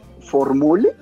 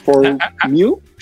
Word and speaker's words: Formule: Se escribe Formule, así for, como de formulario, y mule, ¿sí? Como de mula, este, Formule, ¿sí?